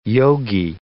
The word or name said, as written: yogi